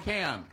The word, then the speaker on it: Pocan: Love you, Representative Mark Pocan. – Mark Pocan. – I said it right.